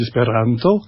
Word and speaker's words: esperanto: Voilà, en fait, en espéranto, on dit esperanto. – D'accord. Il y a des accents toniques. – Il y a un accent tonique toujours sur l'avant-dernière syllabe, donc c'est facile.